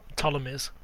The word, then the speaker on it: Ptolemies: how do you say it, brother? – Ptolemies. – Ptolemies, that's it. It's, it's about PT though, so the Ptolemy dynasty, which is the last dynasty yep.